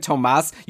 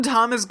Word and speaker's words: Thomas: Patty's telling us that it's just Thomas. I've been saying Tomas. You said Tomas at one point.